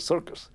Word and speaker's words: CIRCES: And then somebody pointed out, that's CIRCES, so that's circus.